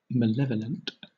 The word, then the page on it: malevolent: British English